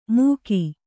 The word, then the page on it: ಮೂಕಿ: kannada